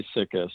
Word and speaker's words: sika: What do the people out there call it? How do they pronounce it? – They say Sika, so that's what okay.